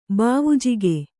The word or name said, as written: ಬಾವುಜಿಗೆ